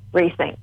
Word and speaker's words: Retiink: So it's called Rethink, but it's spelt Rethink because it's got two I's and no H. So R-E-T-I-I-N-K. Rethink.